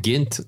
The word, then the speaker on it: Gent: It looks like it's pronounced Ghent to me, but... – Yeah. But I think he said Ghent Ghent.